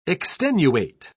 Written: extenuate

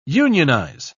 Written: unionize

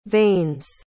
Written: veins

